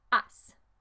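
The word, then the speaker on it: has: That's H-A-S. Remember, in Spanish, you don't really say the H. Maybe if you have a special dialect, you would, but the majority of the time, the H is silent.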